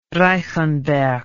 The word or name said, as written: reichenberg